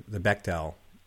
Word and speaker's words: Bechdel: When was the first time you heard about, did I pronounce it correctly, the Bechdel, right, test? – Yeah, you got it.